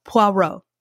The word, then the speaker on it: Hercule Poirot: Hercule, there's a French way of pronouncing it, Poirot.